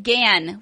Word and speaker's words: Gann: The first one comes from Brian Gann, which rhymes with and, because he finally corrected us.